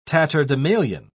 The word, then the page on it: tatterdemalion: British English